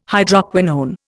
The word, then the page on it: Hydroquinone: Indian